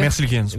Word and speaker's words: Merzlikins: Ils reçoivent aussi le gardien Elvis Merzlikins. C'est-tu comme ça qu'on prononce, les gars? – Merzlikins. – Ouais.